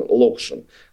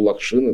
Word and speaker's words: Локшин: Так ставилось ударение в Америке, где он родился в 1981 году, режиссер. Вот, и откуда родом его родители, которые, как, наверное, многие знают, политэмигранты, редкий случай, политэмигранты из Штатов в СССР. Вот. Но он-то вырос а, в России, и русской культурой напитался, что очень хорошо ощущается в, в а, фильме «Мастер Маргарита», поэтому Лакшин – это, ну, естественно, более нам привычное ударение и а, произнесение этой фамилии.